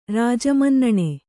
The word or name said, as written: ರಾಜಮನ್ನಣೆ